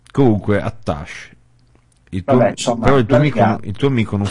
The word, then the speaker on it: attaché: E allora, ho detto, perché devo chiamare attaché in onore dei francesi? – L'hai chiamato attaché, hai solo spostato l'accento tonico.